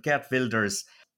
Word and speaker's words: Geert Wilders: Geert, I was getting the pronunciation wrong. My Dutch isn't great, but Geert Wilders.